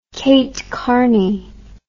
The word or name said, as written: kate karney